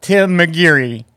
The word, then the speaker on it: McGeary: So let me tell you about Tim McGeary. Did I say that right? – McGeary. – You did. – Perfect. – Yes, you did.